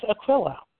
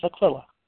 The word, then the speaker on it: Aquila: My name is Aquila. I'm a woman. I'm a woman, and my name is Aquila.